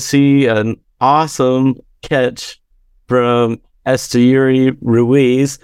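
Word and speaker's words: Esteury Ruiz: And so they've got the pronunciation guide in the media guide, and it says Esteyuri Ruiz.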